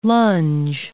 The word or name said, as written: lunge